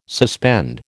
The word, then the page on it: suspend: American English